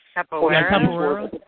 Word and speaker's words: Capoeira: It's a Capoeira.